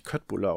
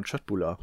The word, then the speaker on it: köttbullar: Ähm, ich habe übrigens letztens gelernt, dass, dass es nicht Köttbullar ausgesprochen wird, sondern Chöttbullar.